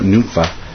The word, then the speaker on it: Nympha: Greet the brethren who are in Laodicea, and also Nympha, I'm sorry, that's how you pronounce that, Nympha, and the church that is in her house.